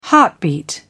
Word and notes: heartbeat: British English

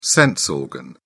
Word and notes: sense organ: British English

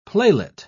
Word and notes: playlet: British English

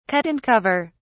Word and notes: cut and cover: British English

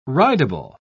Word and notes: ridable: British English

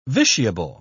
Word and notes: vitiable: British English

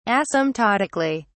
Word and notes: asymptotically: British English

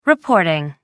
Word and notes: reporting: American English

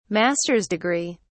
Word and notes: master's degree: British English

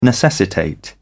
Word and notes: necessitate: British English

